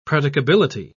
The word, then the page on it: predicability: British English